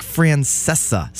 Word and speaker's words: Francesa: I'm going to Mike Francesca. Or is that how you say his name? Mike Francesa style this show.